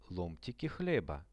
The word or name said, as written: lomtiki hleba